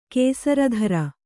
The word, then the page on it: ಕುಸುರಿತಿರಿ: Kannada